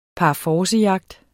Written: PARFORCEJAGT

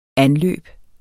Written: anløbe

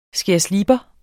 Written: skærslipper

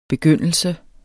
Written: begyndelse

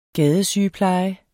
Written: gadesygepleje

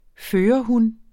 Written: førerhun

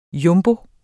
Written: jumbo

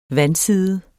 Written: vandside

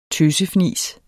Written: tøsefnis

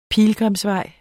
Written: pilgrimsvej

